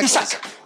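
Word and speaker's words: Isak: Isaac.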